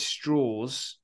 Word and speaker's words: Strauss: You've Robert Downey Jr., who plays Louis Strauss. That's how it's pronounced.